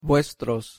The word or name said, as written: vuestros